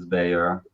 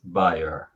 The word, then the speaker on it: Bayer: people call the firm Bayer, right? – Yeah, so that's why I try to adapt to the pronunciation of the audience. So with Americans, it's Bayer. With everyone else, pretty much, it's Bayer.